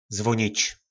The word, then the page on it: zvonic: po našimu